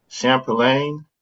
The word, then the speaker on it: Champlain: pronounce that college name? – Champlain College. – Champlain. – Like champagne.